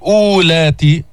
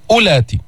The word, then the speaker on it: أولات: وإن كن أولات، هذه الواو لا تلفظ، انظر عليها علامة، هذه الدائرة علامة على زيادة الحرف وعدم النطق به، فما بتقول أولات، ماذا تقول؟ أولات.